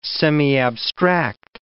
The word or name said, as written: semiabstract